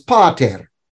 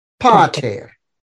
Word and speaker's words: pater: Long A is pronounced like the A in father, and the word I gave you to practice was pater, pater.